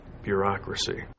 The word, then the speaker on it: bureaucracy: And bureaucracy, it has four syllables and the stress is on that second syllable. Bureaucracy.